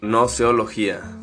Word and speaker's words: gnoseología: Y es así como conocemos algo denominado la teoría del conocimiento, que también se le conoce como noceología. Eh, repito, gnoseología. Se escribe con G-N-O-S-E-O-L-O-G-I-A. E gnoseología. Como cuando vas a decir gnomo, pero que no pronuncias la G.